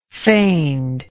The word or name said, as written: feigned